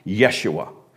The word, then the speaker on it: Yeshua: But there's no J in Hebrew, so it's pronounced Yeshua.